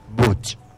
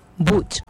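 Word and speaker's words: buch': ¿Qué en lengua yucatán se pronuncia? – Buch. – Buch. – Sí, así como buch.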